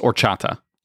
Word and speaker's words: horchata: It's horchata. – Yes. – Just all right. Oh, all right. Okay. That's fine. – Well, hey, we're from North Carolina down here. We don't know that the H is silent.